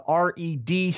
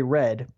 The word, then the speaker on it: R.E.D.: The former Cruiserweight champion made his return to Dragon Gate promotion, and he teamed with the R.E.D. stable. That's how it's said. I'd say Red, but they say R.E.D., so mm-hmm.